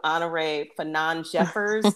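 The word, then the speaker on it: Honorée Fanonne Jeffers: I took note of Honoré Fanon Jeffers. Did I pronounce her name? – Yes, you got it.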